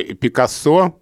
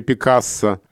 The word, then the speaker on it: Пикассо: Ну, Пикассо или Пикассо, а, где есть два варианта ударения.